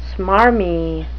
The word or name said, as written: smarmy